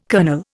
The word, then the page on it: kernel: Indian